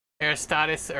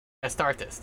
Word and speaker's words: Astartes: Astartes, that's it. That's how you say it.